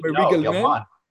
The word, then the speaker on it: Murray Gell-Mann: mention Murray Gell-Mann because maybe I pronounce his name uh, wrong, but regarding well, you, Murray Gell-Mann. Yeah.